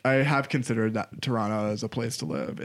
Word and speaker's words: Toronto: That's how Canadians say it. Toronto. – Toronto? – Yeah, you live in Toronto. – It's not Toronto? – No, Toronto.